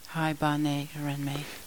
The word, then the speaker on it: Haibane Renmei: We're still watching Haibane Renmei. Did I say that right? – Haibane Renmei.